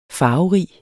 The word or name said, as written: farverig